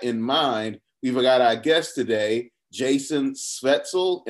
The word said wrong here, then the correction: Szeftel: Szeftel, but pretty close. Jason Szeftel. Okay, all right. We've got Jason Szeftel with us today, and we're going to go a little global.